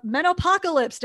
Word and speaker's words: Menopocalypse: You are the author of Menopocalypse. Did I say it right? – Oh my God, I'm giving you a virtual pat on the back as you say that, yes.